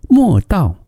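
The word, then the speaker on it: Moldau: 莫尔道是一个德国的发音，而捷克的发音呢叫做维尔塔发。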